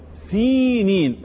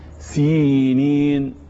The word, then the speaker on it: سينين: أقول سينين، أكيد في خطأ بيحصل، ما تشغلش بالك إيه اللي بيحصل بالخطأ. أنا النهاردة يهمني اليوم، يهمني النطق الصحيح. خلاص الصحيح سينين.